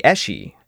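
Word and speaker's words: aos sí: These were the Eshi, the, of course, spelled A-O-S-S-I, so... sure. The Scots, by the way, spell it S-I-T-H, which I have to assume was an inspiration for George Lucas.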